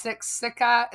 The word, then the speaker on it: Siksika: So Blackfoot Confederacy, and I'm sorry about these pronunciations here, but there's maybe, you know, Nitsitapi, and then there's like the Sitsika. – No, it's Siksika. It's Tappy.